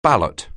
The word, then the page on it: ballot: anglais